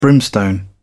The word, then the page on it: brimstone: British English